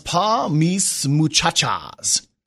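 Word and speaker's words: Pa' Mis Muchachas: I believe it's pronounced Pa Mis Muchachas. – You're probably correct.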